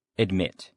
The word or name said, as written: admit